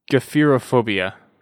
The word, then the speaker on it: gephyrophobia: And I believe it's actually pronounced Gephyrophobia. – Sounds hard to pronounce. – Yeah, Gephyrophobia. Gephyrophobia.